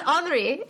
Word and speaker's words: Henri: He was Henri. Is that how they say it in France? – Yeah.